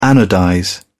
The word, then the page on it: anodize: British English